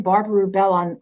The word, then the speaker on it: Barbara Rubel: Plug in Barbara Rubel